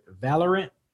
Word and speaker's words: Valorant: And I hope I'm pronouncing this right. Valorant? – Yes. – Really? Am I saying that right? Did I say that right? No? – Yeah, I'm pretty sure. – That's basically it.